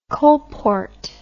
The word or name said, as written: coalport